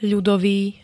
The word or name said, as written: ľudový